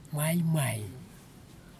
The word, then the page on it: maimai: kwamera